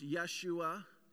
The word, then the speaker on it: Yeshua: Jesus is the New Testament form of the Hebrew name Joshua, pronounced Yeshua in Greek.